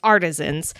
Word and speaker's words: artisans: I said this wrong the last time and I got viciously made fun of. It's artisans.